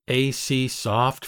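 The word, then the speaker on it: Aiseesoft: And then AC Soft Free Video Converter,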